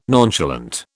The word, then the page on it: nonchalant: British English